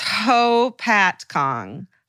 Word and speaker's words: Hopatcong: I went to high school in a small, literally nothing happens town in New Jersey called Hopatakong. And then it says, go ahead, try to pronounce it. it's Hopatcong, Hopatcong, Hopatcong.